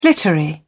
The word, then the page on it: glittery: British English